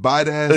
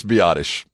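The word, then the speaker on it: Biadasz: Did he call Tyler Biotis bite ass? He did. wait, wait. Maybe he's right. Maybe we've been pronouncing it. No, wrong. I'm pretty sure it's Biotish.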